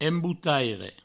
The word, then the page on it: embotaire: emboutaire